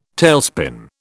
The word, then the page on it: tailspin: British English